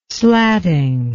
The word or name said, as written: slatting